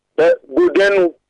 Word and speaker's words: Godenu: How do I pronounce the name of your town? – Godenu. – Godenu, okay. – Godenu. – Godenu, right? – G-O-D-E-N-U.